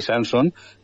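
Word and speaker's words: Sanson: la familia Sansón son seis generaciones, el más famoso es Charlie Henry Sansón que, o Sansón, aunque no lleva acentuación en francés, lo vamos a pronunciar con, con el final o N, pero que eran seis.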